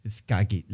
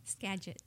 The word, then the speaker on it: Skagit: Ella, aunque de padres mexicanos, ella proviene originalmente del hermoso valle de Skagit. ¿Lo dije bien? No, ¿verdad? – Skagit. – Ah, ¿ya ves? Skagit. Pues sí, así es la vida. ¿Ya ves, Lester? Skagit. Eso, así lo pronuncia muy bonito Monse.